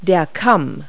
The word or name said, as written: crest